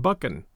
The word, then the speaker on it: Buchan: And one of our Scottish fans did get in touch with me and suggested that the author's name might be properly pronounced as John Buchan. So in the future, we'll work with John Buchan. B-U-C-H-A-N.